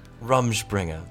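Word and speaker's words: Rumspringa: Rumspringer. – Rumspringer.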